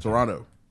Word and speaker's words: Toronto: Toronto. You pronounce last T.